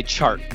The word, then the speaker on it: Chark: Another DJ, not DJ Moore, DJ Clark. – It's actually pronounced DJ Chark. – That's in the outtakes. Clark. Chark. DJ Chark.